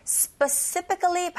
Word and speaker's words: specifically: 그래서 여기서는 people specifically pick 이렇게 강세를 주시면 되는데 specifically 발음하실 때 이 sp 먼저 sp 이렇게 발음해 보세요. sp 그리고 sp specifically 이렇게 발음하시면 됩니다. 좀 어려우신데요. 나눠서 발음한다고 생각하실 때 강세는 뒤에 간다는 거 specifically. Specifically 이렇게 하시면 돼요.